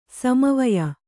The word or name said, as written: ಸಮವಯ